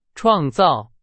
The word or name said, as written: 创造